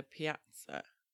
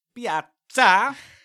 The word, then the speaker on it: piazza: That's a really bad pronunciation of Italian. – No, it's a little area in Huddersfield, for those who don't know. Right. So that's me. – It's actually pronounced piazza.